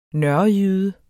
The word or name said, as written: nørrejyde